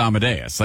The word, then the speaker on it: Amadeus: Yeah, because you're just reading it and you don't know how to say it. Because if you've ever heard it, you know it's Amadeus. Like, it just is.